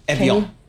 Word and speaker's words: Evian: Evian. Like the water.